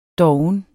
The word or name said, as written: doven